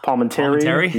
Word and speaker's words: Palminteri: I mean, but that year also, too, we also had Chaz Palminich. Oh, my God, I can never say it right. – Palminteri? Yeah. – Yeah, thank you.